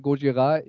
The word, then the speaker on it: Gojira: Ähm, Wikipedia sagt mir, Gojira sei der japanische Originaltitel des Films Godzilla und die Aussprache Gojira, ich habe so ausgesprochen, wie die Bands auf der Bühne selbst ausspricht.